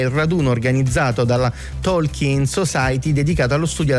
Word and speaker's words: Tolkien: E a proposito di errore, spesso in italiano noi sbagliamo la pronuncia dell'autore del Signore degli Anelli, diciamo Tolkien, Tolkien, invece si dice Tolkien con la I lunga.